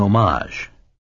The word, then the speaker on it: homage: It's an homage, he said the first syllable so it rhymed with Rome.